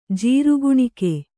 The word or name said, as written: ಜೀರ್ಗುಣಿಕೆ